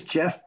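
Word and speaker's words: Geoff: I spell it with a G, and I've had people say G off and goaf and all kinds of other pronunciations. So you all nailed it. Thanks for saying Jeff.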